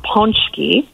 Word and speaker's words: Pączki: Black Club has a list of the top spots around our city where you'll find the delicious Polish pastry, pronounced Pączki.